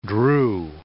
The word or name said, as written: drew